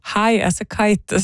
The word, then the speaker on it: High as a Kite: For jeg klarte ikke å lese det riktig, men det altså High as a Kite som riktig uttale på bandet som har denne vakre stemmen til Ingrid Helene Håvik der altså.